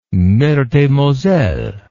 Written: Meurthe-et-Moselle

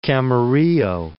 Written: Camarillo

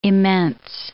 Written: immense

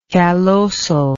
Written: callosal

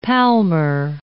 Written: palmar

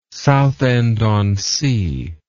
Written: Southend-on-Sea

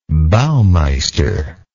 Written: baumeister

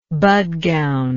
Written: Bhatgaon

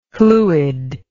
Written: Llwyd

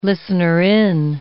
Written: listener-in

